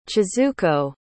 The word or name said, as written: chizuko